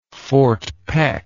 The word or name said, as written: fort peck